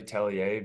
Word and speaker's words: Tellier: Yeah, it's actually really Tellier, but honestly, just Tellier stuck all over the years. So now that's just kind of what I ride with.